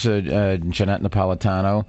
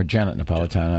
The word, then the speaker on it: Janet Napolitano: The other side is Jeanette Napolitano, or Janet Napolitano, I think she pronounced it Janet.